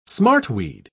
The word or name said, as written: smartweed